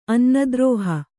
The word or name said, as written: ಅನ್ನದ್ರೋಹ